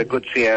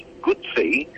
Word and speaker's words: Guzzi: And that Moto Guzzi, I've been to Italy, and I called it Moto Guzzi over there like we do here, but I was quickly told that it's pronounced Guzzi in the same way as you pronounce it pizza.